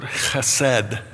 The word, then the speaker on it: chesed: But this is this rich word, chesed. And that's the way you say it. You kind of... You have to kind of do that to say it right. Chesed. It's also sometimes spelled chesed, C-H-E-S-E-D.